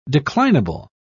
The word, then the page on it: declinable: British English